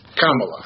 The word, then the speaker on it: Камала: И имя этой дамы, потому что речь идет о женщине, ты правильно сказал, Камала Харрис. Ударение, кстати говоря, на первом слове. Камала.